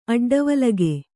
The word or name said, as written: ಅಡ್ಡವಲಗೆ